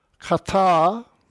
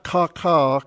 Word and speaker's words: kata: Kata ta erga autu. Toys. Is that you, Daniel? No. It sounds like you're trying to pronounce along with me. See, it's kata, not ka-ka, ka-ka, it's kata-ta, okay? Okay.